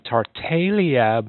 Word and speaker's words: Tartaglia: Anyway, I talked to Jim Costello at the Mount Brow Winery, and the Tartaglia blend, the G is silent, or Tartaglia, was a blend of Cabernet Franc, Shiraz, and a Merlot.